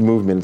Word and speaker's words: MVMT: Movement, MVMT, it's pronounced Movement, it's right.